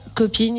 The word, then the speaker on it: Kopin: Alors, littéralement, Cop-In, il s'écrit K-O-P-I-N, parce que depuis plusieurs années, le K vient symboliser le cancer.